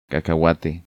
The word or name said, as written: Cacahuate